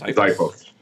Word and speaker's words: Xiphos: Then there's, how do I pronounce this with the X? Xiphos? – Xiphos.